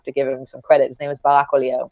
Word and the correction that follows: Oliel: and he's an Israeli guy. He's amazing. Say his name again. Barak Oliel. – Barak Oliel. – Close enough.